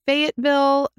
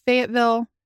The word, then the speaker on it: Fayetteville: He was born in Fayetteville, North Carolina. Not Fayetteville. – That is not how you pronounce it. – If you guys all want to know, it's Fayetteville.